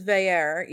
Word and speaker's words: Vieira: Evidently the pronunciation guide says Vieira, even though it's spelled in a way that I would pronounce it Vieu.